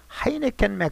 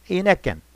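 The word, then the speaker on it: Heineken: 그런데 이상하게도 그 웨이트레스는 하이네켄 맥주를 병으로 가져오는 거 아닙니까? 그제서야 브로다우 씨는 프랑스인들은 단어의 첫자가 H일자에는 H발음을 하지 않는다는 사실을 깨달았습니다. 즉 H발음을 하면 하이네켄이지만 H발음을 하지 않으면 이네켄, 즉 이너캔으로 들리는 것입니다.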